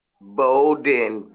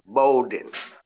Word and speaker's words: Bolden: Bolden, B as in boy, O L D E N, Bolden.